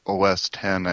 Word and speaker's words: OS X: or OS 10, I think is the correct way to pronounce it.